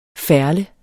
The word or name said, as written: ferle